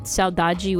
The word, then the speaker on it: saudade: And Portuguese people and people from Cape Verde probably wouldn't pronounce it that way. It'd probably be pronounced saudade or something like that. But in Rio and in different parts of Brazil, it's pronounced saudade.